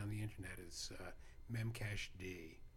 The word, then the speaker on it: memcached: It's not memcached. It's memcached.